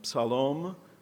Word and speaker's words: Psalom: We met there again with his group, Psalom, which I've been reminded rhymes with shalom, but with the word psalm in it.